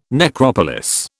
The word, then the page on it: necropolis: British English